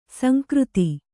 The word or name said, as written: ಸಂಕೃತಿ